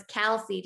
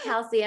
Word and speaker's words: Kelsey: Actually, it's not even, people say my name wrong all the time. It's like Casey or Kaisley or Halsey. And I'm like, no, it's Kelsey. Just like Kelsey.